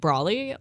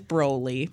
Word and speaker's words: Broly: And he said he's been pronouncing it Brawly, like the Australian slang for umbrella.